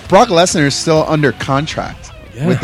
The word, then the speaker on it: Lesnar: Brock Lesnar.